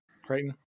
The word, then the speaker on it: Crichton: Crichton.